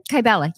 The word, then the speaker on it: Kybella: So Kybella, is that how you say it? – Kybella, yes.